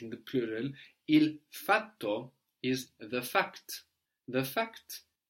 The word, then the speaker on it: fatto: Fatto. – And you can hear I'm pronouncing two T's here, fatto, F-A-T-T-O.